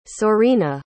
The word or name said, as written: Sorina